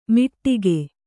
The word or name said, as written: ಮಿಟ್ಟಿಗೆ